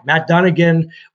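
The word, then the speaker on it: Dunigan: I can't pronounce that last name. – Matt Dunnigan.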